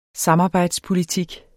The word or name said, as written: samarbejdspolitik